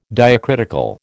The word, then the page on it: diacritical: American English